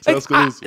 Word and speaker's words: Tuscaloosa: Tuscaloosa.